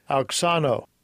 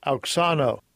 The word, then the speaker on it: auxano: Auxano. Auxano. – Auxano.